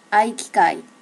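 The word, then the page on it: aikikai: native Japanese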